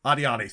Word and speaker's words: Adjani: Isabelle Adjani, hopefully I'm not Adiani. – mispronouncing her last name, Adiani, thank you.